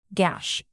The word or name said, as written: gash